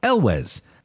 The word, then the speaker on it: Elwes: It's pronounced like it's spelled Elwes.